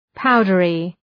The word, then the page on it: powdery: ‘paʋdərı